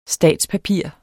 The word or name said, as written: statspapir